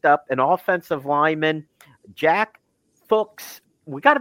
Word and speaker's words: Fuchs: It's spelled F-U-C-H-S. I read somewhere, and I guess his father was an NFL player and is now coaching. And somewhere someone looked it up, his father, the pronunciation, and they said it rhymes with books. So it's Fuchs.